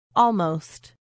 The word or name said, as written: almost